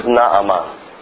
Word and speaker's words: Naaman: But his name in actual Hebrew is Naaman. That's the way that they would have pronounced it in the Hebrew and in the scriptures, Naaman.